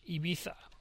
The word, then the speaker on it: Ibiza: Here in Spain, so, or if you're Spanish, Ibiza.